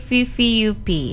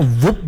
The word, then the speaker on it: VVUP: Selain Carmen teman Pro 2 dan juga Kim dari VVUP. Benar enggak sih bacanya? – Benar banget. Tadi aku bacanya kayak whoop begitu.